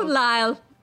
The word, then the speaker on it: Lisle: So when I got the card, the name was spelled L-I-S-L-E. – Yeah. – Right? And I decided it was Lyle. A-I-S-L-E is Isle, so L-I-S-L-E should be Lyle.